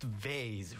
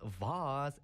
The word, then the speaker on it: vase: It's pronounced vase, right? I've argued about it with my mother for such a long time. I was like, hey, it's vase, but she was always like, no, it's vase, and even now we still bicker about it.